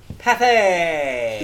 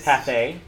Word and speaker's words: Pathé: I think so, because it usually has an accent on the E. – There is. – I believe it's Pathé. – Yes, not Path.